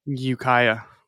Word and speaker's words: Ukiah: Ukiah.